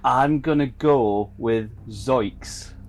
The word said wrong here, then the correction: zoinks: It's zoinks. Zoinks. With an N. Z-O-I-N-K.